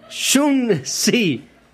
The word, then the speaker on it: Xunzi: Xun Zi.